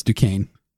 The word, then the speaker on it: Duquesne: It's Duquesne. – Yeah, what's the matter? You don't know how to pronounce stuff? Sorry, it just caught me off guard. Duquesne University in Pittsburgh, in the U.S.